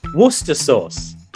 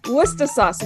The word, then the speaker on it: Worcester sauce: Okay, so how do you pronounce that word? – Worcester sauce. – Worcester sauce. We call it Worcestershire.